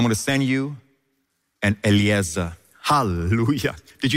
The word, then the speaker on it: Eliezer: Now, Lazarus, in Hebrew, Lazarus, which Jesus would speak Aramaic and Hebrew, it would be Eliezer. Lazarus means helper. Eliezer, of course, means helper in Hebrew. And that's how they say it, Eliezer. So Jesus wouldn't be saying Lazarus, like the Greek would say Lazarus.